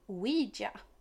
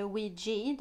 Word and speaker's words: Ouija: Ja, och som ni antagligen har sett på titeln på detta avsnitt så har jag ju valt då att prata om Ouija. – Mm. – Eller Ouija, det får man säga vilket som man vill. Båda uttalen finnes.